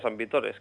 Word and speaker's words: San Vitores: En la zona donde es originario, por ejemplo, ahí, sin lugar a dudas, se pronuncia San Vitores, claro. No tiene nada que ver con Víctor, con el nombre de Víctor.